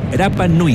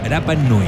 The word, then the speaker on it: Rapa Nui: Así de cerca estás y apuestas el Mundial con Polla Experto en Isla de Pascua, en Rapa Nui, como me enseñaron a decir, 88.3 ADN, número 1 en deportes. – ¿Cómo se dice? – Rapa Nui.